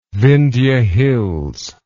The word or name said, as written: Vindhya Hills